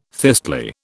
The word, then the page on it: thistly: British English